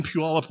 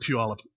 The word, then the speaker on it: Puyallup: And when I was 9 years old, my family lived briefly in Puyallup, Washington. There's 2 interesting things about Puyallup. One is its name. What an interesting name, Puyallup.